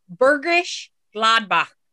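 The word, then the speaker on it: Bergisch Gladbach: I think the pronunciation is much closer to Burgish Gladbach.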